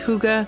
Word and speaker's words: Hygge: It's spelled H-Y-G-G-E and yet pronounced Hygge because the word is Danish.